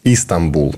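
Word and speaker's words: Истанбул: И, кстати, по, по Стамбулу то же самое мне говорили, что вы неправильно произносите название города. А, Истамбул, надо говорить.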